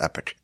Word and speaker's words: epoch: It already has to begin the next epoch. It's actually pronounced epic.